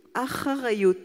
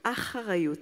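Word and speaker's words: Acharayot (אחריות): Responsabilité en hébreu, ça se dit Acharayot, c'est pas facile à prononcer. Acharayot.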